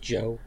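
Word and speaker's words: Zi-O: And I also feel like it should be pronounced Joe, because I feel like that's a Z in there, and I know the Japanese, and the Z is pronounced as J.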